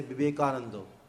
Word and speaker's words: Vivekananda: Like if you ask a Bengali to pronounce Vivekananda, he would say Vivekanando.